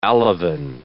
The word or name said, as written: alevin